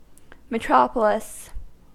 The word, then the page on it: metropolis: American English